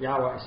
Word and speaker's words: Yahweh: But Yahweh seems to be the current standard accepted pronunciation of God's name. As I've mentioned before, one of my seminary professors argued that it has three syllables, not two, with an O sound in the middle, which makes something like Jehovah.